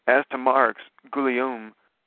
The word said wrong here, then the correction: Guillaume: observes, did I butcher that guy's name, Guillaume? – It's Guillaume. – Guillaume observes. – It's actually the French equivalent to Williams.